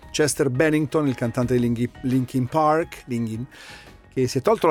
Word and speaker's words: Linkin: Abbiamo riascoltato appunto il Linkin Park, dove Linkin, non so se lo sapete, è la contrazione di Lincoln, cioè è Lincoln scritto come si pronuncia, perché il parco in questione è il noi in Italia diremmo il Lincoln Park, ma loro dicono Linkin.